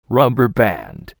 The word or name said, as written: rubber band